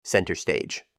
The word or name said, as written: center stage